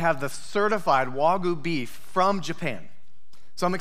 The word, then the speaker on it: Wagyu: Mangu beef? Wagyu beef.